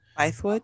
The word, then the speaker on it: Bythewood: Is it Bythewood or Bythewood? – Bythewood.